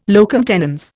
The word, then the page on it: locum tenens: Indian